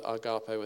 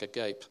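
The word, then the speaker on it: agape (ἀγάπη): You probably haven't seen this written like that, agape with an H on the end, but I always think that agape without an H sounds like a gape. And so I kind of, it's three syllables there, agape.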